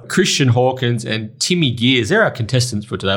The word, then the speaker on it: Gears: Christian Hawkins and Timmy, is it Gears or Jeers? – Gears. – With a hard G? – Hard G. I get all sorts. I get Greer, Gear, Jeers, Greers.